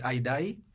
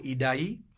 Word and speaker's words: Idai: The Ethiopian Airlines, the Christchurch, the cyclone, I think they're pronouncing it Idai or Idai. It's spelled I-D-A-I.